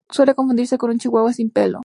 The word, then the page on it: pelo: ispanu